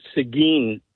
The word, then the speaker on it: Seguin: The little town is actually pronounced Seguin, Texas.